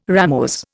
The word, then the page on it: ramose: Indian